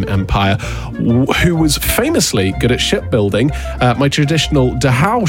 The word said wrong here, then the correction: dhow: ship, or I believe it's just pronounced Dow, but it's spelt D-H-O-W, became a symbol of my seafaring with its distinctive triangular sails.